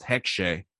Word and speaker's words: Hexe: So the H stands for hex, and I think that it's actually pronounced Hexe uh, in German.